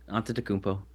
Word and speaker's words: Antetokounmpo: Antetokounmpo? – Antetokounmpo.